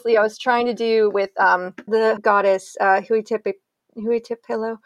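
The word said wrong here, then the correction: Hine-nui-te-pō: Hine Nuitipo, I believe is how you say it. I hope I didn't screw it up. – Hine Nuitipo.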